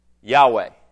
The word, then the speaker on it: Yahweh: But the Hebrew pronunciation of this word is Yahweh. And sometimes the W can sound like a V, so some people say Yahweh.